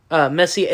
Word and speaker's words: Messier: Is that R silent? – Messier, it's French. – Oh. – Or French Canadian. – So the R is silent, okay.